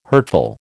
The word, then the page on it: hurtful: American English